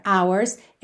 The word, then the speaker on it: hours: Então, o primeiro som que a gente tem da palavra hours é o som da letra O.